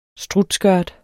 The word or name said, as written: strutskørt